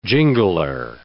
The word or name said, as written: jingler